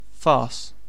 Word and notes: farce: British English

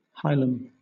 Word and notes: hilum: British English

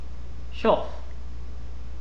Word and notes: chough: British English

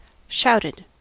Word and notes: shouted: American English

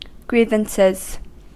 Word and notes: grievances: American English

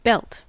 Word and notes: belt: American English

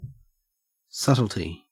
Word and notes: subtlety: Australian English

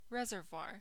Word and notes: reservoir: American English

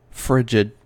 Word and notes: frigid: American English

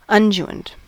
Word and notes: unguent: American English

